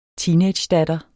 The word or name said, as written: teenagedatter